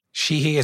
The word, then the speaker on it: Sheehy: Sheehy is his name. Sheehy, okay. Right? Isn't that how you say it? – Shout out to pronouns. – It's S-H-E-E-H-Y.